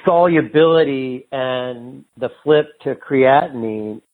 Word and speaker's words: creatinine: Creatinine? – And I've heard that. Yeah, is that how you say it? Creatinine? – Creatinine, yeah.